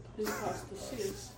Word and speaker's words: hypostasios: Remember the word, can you say the word hypostasios? – Hypostasios. – That's a 5-syllable word, man.